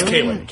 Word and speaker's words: Kaling: I don't really, not that crazy about the Mindy Kaling character, because I don't think she gave herself much to do. – Is it Kaling or Kaling? – It's Kaling.